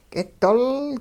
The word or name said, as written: k'idol